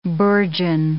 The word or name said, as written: bourgeon